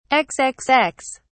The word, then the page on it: xxx: British English